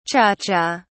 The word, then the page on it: cha cha: British English